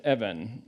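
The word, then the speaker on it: even (אבן): The word is pronounced even.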